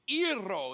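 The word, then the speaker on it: iro (αἴρω): It's iro is the way you'd say it, but it's spelled aero, A-I-R-O.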